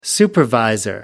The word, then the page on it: supervisor: British English